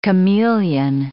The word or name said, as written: chameleon